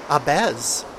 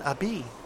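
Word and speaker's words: Ahbez: I just learned while doing this show over the past 7 years off and on, something like that, maybe eight, that I was pronouncing Eden Abez, when it should be Eden Abi, or Gabor Szabo.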